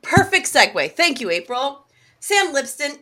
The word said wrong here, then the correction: Lipsyte: is a multi-published author. – Lipsight. – God damn it. You're going to say his name in the closeout. Sam Lipsight is a multi-published author and screenwriter.